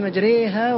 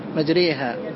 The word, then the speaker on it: مجريها: وقد قرأ حفص بالإمالة في قوله تعالى: بسم الله مجريها ومرساها، في هذه الكلمة مجريها، وهي تشبه نطق أول حرف في اللغة الإنجليزية.